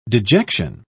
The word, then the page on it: dejection: British English